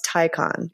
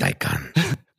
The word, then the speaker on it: Taycan: but it's pronounced Taycan. – Yeah. – For all those in the listening audience. – And now, like, as someone who actually does like speak some German, that is the logical pronunciation. My Americanness has clearly won out. – Yes. And how do you pronounce it, with your best German Porsche accent? Taycan.